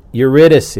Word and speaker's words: Eurydice: Eurydice.